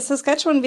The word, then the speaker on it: Saskatchewan: Ja, Saskatchewan, wir sagen tatsächlich im Englischen immer easy to draw, hard to spell, also leicht zu zeichnen und schwer auszusprechen.